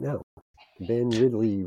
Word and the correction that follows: Reilly: I say Riley. That's Riley. How I've always known it, Ben Riley. Riley.